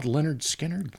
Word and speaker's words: Lynyrd Skynyrd: How about Lynyrd Skynyrd? – Lynyrd. Skynyrd. Yeah. Never, we'd get in arguments how to properly pronounce that. – Lynyrd? – A cousin of mine finally said, dummy, it's just Lynyrd Skynyrd.